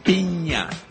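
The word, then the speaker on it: piña: Pina. Escape. – Pina. – Thank you.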